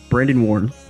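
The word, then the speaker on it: Brandon Warne: I am here with Brandon Warren.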